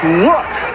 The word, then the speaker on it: lewk: By the way, that's look, but it's spelled L-E-W-K.